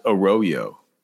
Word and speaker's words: Arroyo: Like the Mexican word for the Spanish word for ditch, but he says it's pronounced Arroyo. – Arroyo. – So not Arroyo, Arroyo.